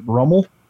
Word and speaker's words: Rummel: I mean, Louisiana is a great state for high school football as it is, but he plays at Archbishop Rummel, mm-hmm. which I don't know if I pronounced that right. – You did. – I think I did. – Yes, he did.